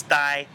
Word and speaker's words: Tai: Spelled with a T, but we've already learned it's Dai. – So here is one of my bugbears. Why spell it with a T when it's pronounced D? Dai.